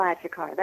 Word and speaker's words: Achecar: Achecar? Well, well pronounced. Giselle Achecar.